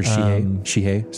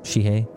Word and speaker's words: Shihei: It's a sh, right? Not a she. Yeah, sh. Shihai. Or Shihei, sorry. – Shihei. I'm kind of surprised we've never talked about Shihei.